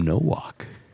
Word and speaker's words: Nowak: I think it's Tim Nowak. – Well, that's right. – I think we're going to say it right. – You said it right.